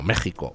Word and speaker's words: México: La pronunciación es J. Y de hecho, pues en México tienen ustedes pueblos como Guanajuato, que se pronuncia como México con J, y que se escribe con J, cuando durante todo el siglo XIX permaneció la coexistencia de las dos grafías, Guanajuato con J y Guanajuato con X pronunciado J.